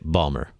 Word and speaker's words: Baltimore: And you had the opportunity to visit an urban ministry in Baltimore, correct? – Mm-hmm. Correct. – Or as the locals say it, Balmer.